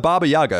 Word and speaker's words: Baba Yaga: Become the Baba Yaga.